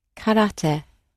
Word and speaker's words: karate: And karate might not be the way you pronounce it, but shall we have our native speaker say that word? – Karate.